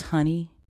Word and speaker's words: Hunny: And I am your host, Honey. I spell that H-U-N-N-Y.